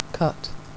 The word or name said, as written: cut